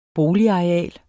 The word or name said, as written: boligareal